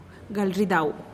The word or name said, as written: gal ridau